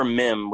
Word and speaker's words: Mihm: Mem as in, like meme, but without the E at the end? – As in Christopher R. Mim, which is spelled M-I-H-M.